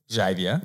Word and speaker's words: Xavier: Kind of like my auntie taught at a school near Shepparton Primary School and the boy's name spelled X-A-V-I-E-R. Xavier. – Yeah. – The parents pronounced it Xavier. – Xavier. Professor Xavier.